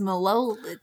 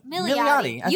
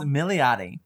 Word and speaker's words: Milioti: I thought it was Melody. – Melody. No, Milioti. – You said it. – I said it. – You had a flow. – Yes, Milioti. The publisher is Harper Audio.